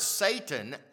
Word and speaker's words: Satan: The word Satan actually is pronounced Satan in Hebrew, Satan.